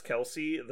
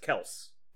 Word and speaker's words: Kelce: Did you know that Travis Kelce, the tight end for the Kansas City Chiefs, his last name is not Kelce? – Is it Kelce? – Kelce. It's just Kels. – Kels. – There's no E.